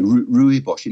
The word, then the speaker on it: rooibos: Yeah, that's right, rooibos. In fact, I gave my mum a cup of rooibos tea once. – Yeah, and I said to her, "This tea is rooibos."